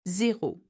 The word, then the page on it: Zero: French